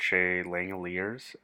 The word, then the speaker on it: Langeliers: But Shea Langeliers, I believe, is how you pronounce that catcher's name.